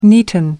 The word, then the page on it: neaten: American English